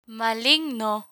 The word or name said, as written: maligno